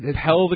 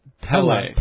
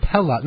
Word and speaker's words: Pelle: Pell the Conqueror. Pell Aerobrerin. – I think you pronounce it Pella. – Pella. – No, it's not Pella. Pella, I think it is. – Pella the Conqueror. – Pella the Conqueror. It's okay. Spelled P-E-L-L-E,